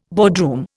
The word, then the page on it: boardroom: Indian